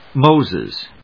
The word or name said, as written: Moses